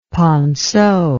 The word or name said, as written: ponceau